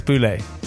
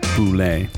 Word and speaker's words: Boulle: it's not Boulay. There's no accent on the E, so you know, also, you know, we did in bed with Jamie with her, and we actually phoned you up.